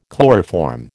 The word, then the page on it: chloroform: American English